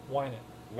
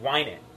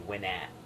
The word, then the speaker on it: Winant: Winant. – Winant. – Yeah. – I always say Winat or something like that.